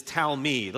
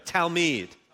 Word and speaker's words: talmid: So in the Hebrew, the word for disciple is talmid. Let's everybody say that together. Everybody say it. Ready? Set, go. Talmid.